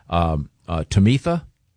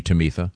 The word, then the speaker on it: Tamitha: T-A-M-I-T-H-A, rather than Tamitha. I think it would be Tamitha. – Tamitha is a sweet, sweet girl. Don't you think it's Tamitha, like Tabitha? – Wouldn't that be? Okay, it could be. I'm sorry. Tamitha.